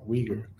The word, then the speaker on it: Weger: Han och Weger heter han, va? – Ja, Mackenzie Weger här som jag pratade ner om förra veckan. Ja, Weger. Ja, mina uttal.